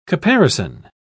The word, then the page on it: caparison: British English